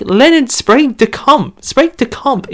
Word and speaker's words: Sprague: It's pronounced Sprague. Sprague de Camp.